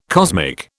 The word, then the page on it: cosmic: British English